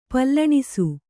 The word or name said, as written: ಪಲ್ಲಣಿಸು